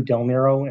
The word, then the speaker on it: Del Nero: My name is John Del Nero, and it's spelled D-E-L-N-E-R-O, and I represent the appellant Laco Properties.